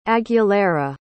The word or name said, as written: aguilera